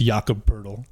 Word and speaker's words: Jakob Poeltl: I'm pretty sure his name is pronounced Jakob Poeltl. – Jakob Poeltl? – Yes. – It's P-O-E-T-L. – Yes, it's Jakob Poeltl, dude. I know it is.